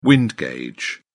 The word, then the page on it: wind gauge: British English